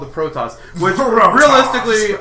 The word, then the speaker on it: Protoss: I'm embarrassed I just realized I've never heard Protoss pronounced. I was like, oh, Protoss.